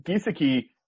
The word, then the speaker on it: Gesicki: Sorry, I in my job I work with a doctor whose name is spelled like this, and it's Gesicki, and I used to call him Gesicki, and he like got real mad at me and corrected me, and so now I pronounce it Gesicki.